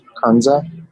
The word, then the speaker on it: Kernza: We're also making sourdough every day out of a perennial grain called Kernza, which is spelled K-E-R-N-Z-A, kind of like corn kernel.